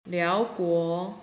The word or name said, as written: liao2guo2